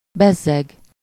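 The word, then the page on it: bezzeg: IPA: /ˈbɛzːɛɡ/; Hungarian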